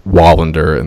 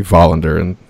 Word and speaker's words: Wallander: There's another series that's pretty famous from there called Wallander, in the sort of anglicized pronunciation would be Wallander in Swedish.